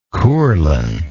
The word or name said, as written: courlan